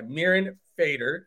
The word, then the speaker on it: Mirin Fader: I have the absolute pleasure of being joined by Mirin Fader.